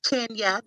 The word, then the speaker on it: Kenya: And Kenja? – Hi, it's Kenya. The J is silent, dog.